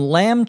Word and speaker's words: lamb: Samantha says, "I'd like some lamb chops, pork chops, and some beef, but I'm not sure which cuts I want yet." Lamb, L-A-M-B, notice the B is silent when we pronounce the word, is meat that comes from a young sheep.